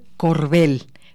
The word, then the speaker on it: Corbel: Vamos con Cecil Colbert. Dans la tête pour quand il fait froid, j'ai déjà moi. Le llaman aquí a Andrea y preguntaban el nombre de la cantante. Se escribe Cecile, tal cual, Corbel, con B labial.